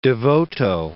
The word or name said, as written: De Voto